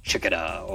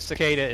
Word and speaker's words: Cicada: It was definitely a different mech, and then I saw Cicada, or as some call it, Chicada, or whatever you want to pronounce it. Yeah, Cicada, but... Cicada is the proper pronunciation.